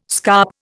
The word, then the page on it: scarp: Indian